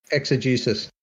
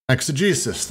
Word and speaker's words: exegesis: Exegesis. – Exegesis, thank you.